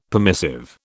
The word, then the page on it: permissive: British English